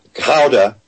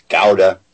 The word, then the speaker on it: Gouda: And it turns out he's a master Delft painter, and he spent a lot of time in the city of Gouda, which is the way they pronounce Gouda.